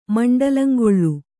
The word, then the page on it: ಮಂಡಲಂಗೊಳ್ಳು: kannada